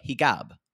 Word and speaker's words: hijab: And I kept saying, what is when they, hijab, he's like, no, actually In Egyptian Arabic, there is no j. It is, so they pronounce it g, hijab.